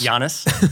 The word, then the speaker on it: Giannis: Call him Jonas? – Giannis. Sorry.